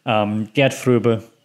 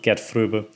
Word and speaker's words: Gerd Fröbe: And again, Gerd Fröbe, that's how you pronounce it, Gerd Fröbe, as Goldfinger.